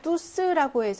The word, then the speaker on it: tous: 그리고 뚜의 발음이 부정 형용사에서는 뚜지만 부정 대명사에는요. 뚜스라고 해서 S가 발음이 납니다. 이렇게 발음이 나요.